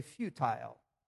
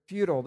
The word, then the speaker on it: futile: If we were in Great Britain, I would say futile. But here it's futile.